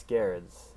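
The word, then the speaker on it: Gerads: Yeah, it's Garrod's. Yeah. – Okay, Garrod's. I've been right all along. That's all Garrod's. I know. – Yeah, the hard G.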